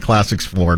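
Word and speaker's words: Classics IV: And he's like, Classics 4, and we laughed, and that's my confession for the day.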